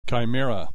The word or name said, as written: chimera